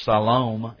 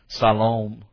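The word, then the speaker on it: Siloam: I should say Salam is the way you pronounce properly. That O is long vowel, not short vowel. Salam.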